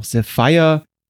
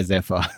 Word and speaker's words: Zephyr: Wie spricht man diesen Schuh richtig aus? Ähm, ich habe auch von Topo gehört, dass in Deutschland auch Sapphire wohl akzeptiert wird bei der Aussprache. Die Amis auf YouTube sagen Zephyr, dann ich bleibe jetzt einfach mal bei Zephyr. okay, dann einigen wir uns darauf.